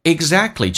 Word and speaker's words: exactly: Exactly 这个单词跟我们以前说的是完全两回事。第一个，首先 k 是不发音，因为两个辅音在一起，那个 k 就不用发音，变成 exactly，而且 t 也不发音， t 跟 l 组合在一起， t 是可以不发音的，那这个单词就变成 exactly， exactly 跟我们以前说的 exactly 那是两回事。